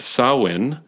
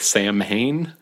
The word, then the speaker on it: Samhain: It looks like Sam Hain, but I looked it up and it is pronounced Samhain. – Okay. – So, just a fun fact there. The M completely disappears. It's one of those famous silent M's.